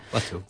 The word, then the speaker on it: plateau: Plateau. – French word. We pronounce it like français. Plateau. Yeah.